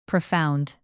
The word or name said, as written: profound